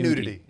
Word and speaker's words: nudity: Say nudity. – Nudity. – It's three syllables.